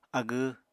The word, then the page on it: agw: Apatani